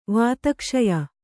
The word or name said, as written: ವಾತಕ್ಷಯ